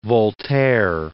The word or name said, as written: Voltaire